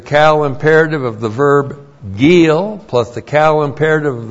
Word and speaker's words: gil: G-I, long I-L, gil.